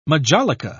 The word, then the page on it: majolica: British English